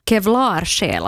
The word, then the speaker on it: Kevlarsjäl: Det ska uttalas kevlarsjäl, alltså som en pansarsjäl. Okay. Att man har så här pansar runt sin själ.